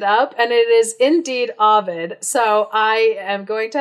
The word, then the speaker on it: Ovid: Well, Google was like, it's Ovid.